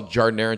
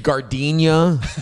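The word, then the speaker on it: giardiniera: Oh, it's phonetically spelled Jarnera. Because being the guy that has a national voice, if you will, you know, anybody could sell Jarnera in Chicago, but my goal is to sell it nationwide. And the biggest hurdle is getting people to say it correctly and pronounce it.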